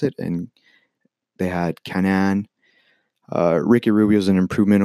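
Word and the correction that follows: Canaan: Isaac Cannon, it's not Kanan.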